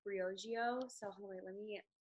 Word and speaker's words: Briogeo: and it's pronounced Briogeo.